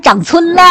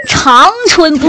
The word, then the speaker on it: 长春: 对啊，是不是长春啊？长春是不是？ – 什么玩意儿长村呢？那至少得一个星期没搓澡了才长村呢。小弟呀、啊、那是个多音字啊，长春不是不是长长个子的长啊，不读长春啊。